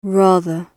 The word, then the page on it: rather: British English